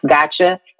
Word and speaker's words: Gotja: My business page is Gotcha Social, and it's spelled GOTJA Social. So I know that when you read it, you're probably like, wait, how do you say that? And it's pronounced Gotcha.